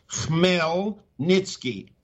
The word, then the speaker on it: Khmelnytsky: Well, now to get to your article, you cite and I'll pronounce it as best I can, the Melnitsky? – I'll pronounce it for you because Chris Busby misspelled it. And I had to respell it. It's Khmelnytsky. Khmelnytsky